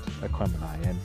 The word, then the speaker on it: Aquemini: So the title's a portmanteau, which is when you put two words together, of Big Boy's zodiac sign, which is Aquarius, and Andre 3000's, which is Gemini. So you get Aquemini. And of course, when you get in the album and hear the title track, you'll hear them pronounce it like that.